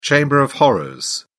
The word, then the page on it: chamber of horrors: British English